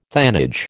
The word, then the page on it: thanage: American English